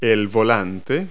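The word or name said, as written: steering wheel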